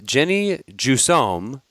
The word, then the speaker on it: Jennie Jussaume: Joining us today on the Simply Human podcast, very excited to have Jennie Jussaume.